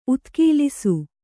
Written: ಉದುಗಲಿಸು

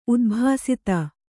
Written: ಉದ್ಭಾಸಿತ

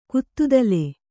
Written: ಕುತ್ತುದಲೆ

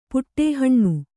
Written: ಪುಟ್ಟೇಹಣ್ಣು

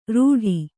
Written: ರೂಢಿ

